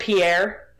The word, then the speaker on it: Pierre: It's spelled Pierre, but it's pronounced Pierre because the Midwest is weird and Pierre.